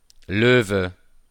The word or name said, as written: Loewe